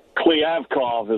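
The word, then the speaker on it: Kliavkoff: We just heard a lot from the new commissioner of the Pac-12, George Kliavkov. Am I saying that right, Bill? It's going to be a lot of getting used to that name, I guess, as we get into it. – Kliavkov is what I saw the pronunciation on today.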